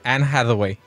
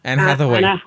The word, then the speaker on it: Anne Hathaway: ¿Cómo se escribe? ¿Cómo se pronuncia? – Anne Hathaway. – Hi, ¿how? – Anne Hathaway. – Como hack dog.